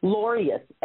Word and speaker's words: Lorious: Okay, the easiest way to remember it first, let me spell it like you said, Loreus, L-O-R-I-O-U-S. Think of it this way. I told you I was a church girl, right? – Yes. – Think of glorious with no G.